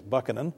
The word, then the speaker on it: Buchanan: And James, I believe the correct pronunciation actually is Buchanan, and Andrew Johnson, because the one failed to avert the Civil War and the other botched the aftermath of the Civil War.